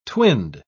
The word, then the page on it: twinned: British English